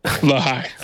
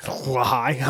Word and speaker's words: Lahai: I just put out a rankings on our Instagram, and I had, I don't know how you say it, Lanai by Sampha. – Lahai. – Lahai. Oh, I had it as an N.